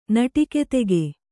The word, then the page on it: ನಟಿಕೆತೆಗೆ: naṭike tege